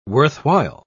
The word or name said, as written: worthwhile